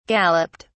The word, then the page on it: galloped: British English